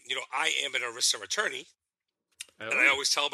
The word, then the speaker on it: ERISA: ERISA.